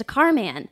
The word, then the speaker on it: Carman: I say Carmen because his name was Carmen, M-E-N, and he changed it to Carman.